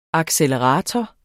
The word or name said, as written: accelerator